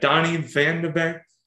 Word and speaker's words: Donny Van de Beek: Donny Van de Beek, is that how you pronounce his name? – Yeah.